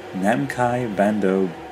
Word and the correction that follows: Namco Bandai: ba- Namco Bandai. I believe that they, uh, with their official outlets, I made it. I actually managed to pronounce it.